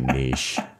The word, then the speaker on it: niche: So my problem is I had a podcast for years spelled N-I-T-C-H. So that my mouth just doesn't want to say niche. – It's okay. You can say it however you like. – I've looked it up. Both pronunciations are allowed.